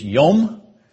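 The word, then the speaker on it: yom (יום): And the pronunciation of that word in Hebrew is yom.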